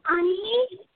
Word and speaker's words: Ani: Oh, my name is Ani. – What's your name? Your first name? – Ani. – Oh, okay. How do you spell it? – It's A-N-I.